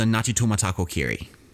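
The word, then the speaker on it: Ngāti Tūmatakōkiri: It was called the Ngatutumataku Kiri. – That was very good. Great pronunciation.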